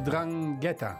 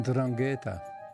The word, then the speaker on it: Ndrangheta: Drangheta, denk ik. – Frits Spits, sprak hij het goed uit? – De klemtoon op het einde was net ietsje anders, maar ik vond dat hij het goed deed.